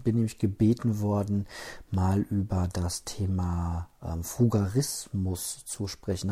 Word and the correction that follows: Frugalismus: Habe ich das überhaupt richtig ausgesprochen jetzt? Ähm, muss mal ganz kurz gucken. Ich finde das ein ganz komisches. Ähm, Wort. Frugalismus, genau.